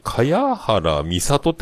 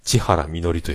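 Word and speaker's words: 茅原実里: えー、この人の声の人が歌うんですけど、これなんかね、かやはらみさとって書いて、千原みのりと読むっていうね。えー、かやはらじゃねえのかっていうね。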